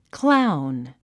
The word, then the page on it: clown: kláun; クラウン